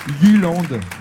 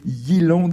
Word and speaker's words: Jylland: En danois, ça donne quelque chose comme Jylland. Je ne suis pas sûr de prononcer parfaitement le danois, je le confesse, mais je crois que c'est entre Jylland et Jylland.